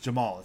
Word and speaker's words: Jamahal: Khamzat Chimaev is pronounced Khamzat, not Hazmat, and Jamal Hill is just Jamal. It's not Jemma Hall.